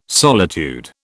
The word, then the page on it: solitude: British English